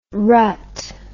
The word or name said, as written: ratha